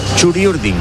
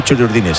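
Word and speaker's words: Txuri Urdin: Esperamos a que por la bocana aparezcan los futbolistas Churi Urdines. No, Churi Urdin. No se dice Churi Urdines, se dice Churi Urdin.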